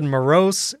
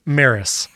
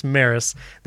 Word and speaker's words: Meross: and it's pronounced Maris. Way off. I was way off. It's spelled M-E-R-O-S-S, but it's pronounced Maris.